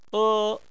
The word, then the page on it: e: 1